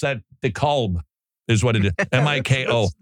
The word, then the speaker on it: DeKalb: I know there's an L in there, but that's, you know, DeKalb. Just cab. – Oh, okay. – In fact, it's one syllable. Cab. Like skeet. – Skeet. – Yeah, let's go eat a skeet. – That's right. – Cab. Just one word.